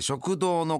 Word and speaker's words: 食道: え食堂の漢字は、食の道と書いて食堂でございます。